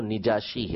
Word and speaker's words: نجاشی: عربی کے اندر جو زیادہ فصیح ہے، زیادہ بہتر ہے، وہ نجاشی ہے، نون کے نیچے زیر کے ساتھ کسرہ کے ساتھ نجاشی پڑھنا،